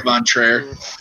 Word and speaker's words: von Trier: Number 8 is Los Von. How do you say it? Lars Von Trier.